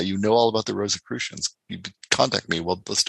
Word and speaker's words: Rosicrucians: Did I ever improve my pronunciation of the Rosicrucians? – You get it. That sounds right.